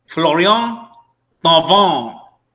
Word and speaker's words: Florian Thauvin: Florian Thauvin.